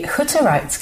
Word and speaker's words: Hutterite: But looking deeper, I noticed that you grew up in the States until the age of 7 in a Hutterite community. Have I said that right? – Yes, you have.